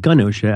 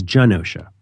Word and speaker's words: Genosha: I thought it was Gunosha. I used to pronounce it Gunosha. I think it's Genosha. – Well, it depends on who's saying it. If Magneto's saying it, I think it's still Genosha.